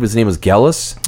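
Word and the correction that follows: Gelus: Jealous, I think is Jealous. How to pronounce it, yeah.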